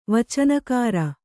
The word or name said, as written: ವಚನಕಾರ